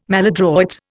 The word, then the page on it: maladroit: Indian